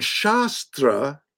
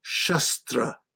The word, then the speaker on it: Shastra: the the um this is when we turn Shastra into Shastra. Mm. Okay? Yes, yes. Scripture into weapons. And it's the difference between a long A and a short A.